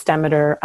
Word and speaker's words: Demeter: And so the idea is that Demeter, and I've heard it pronounced Demeter and Demeter, but I believe it's Demeter,